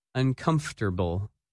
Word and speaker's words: uncomfortable: That word is uncomfortable, and its opposite, comfortable. When you say this word, you do not pronounce some of the letters that you see in the written word.